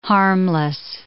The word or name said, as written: harmless